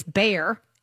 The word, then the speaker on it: Bayer: Bayer, we pronounce it here in the States, Bayer, ADRs, which are like shares.